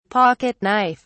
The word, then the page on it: pocket knife: British English